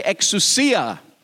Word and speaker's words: exousia: Everyone say exousia or exousia, depending on your uh, accent or pronunciation, uh, pronunciation.